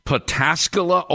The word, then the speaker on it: Pataskala: Dorsey, you mentioned solar, and I see there's a plant, I believe, in Pataskala, Ohio.